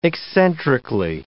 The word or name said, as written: eccentrically